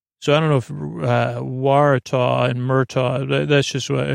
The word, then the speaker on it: Waratah: Again, their state flower is the Waratah. Almost rhymes with Murtaugh.